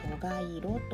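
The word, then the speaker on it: 承和色: 承るという漢字に平和の和に色で蘇我色と読みます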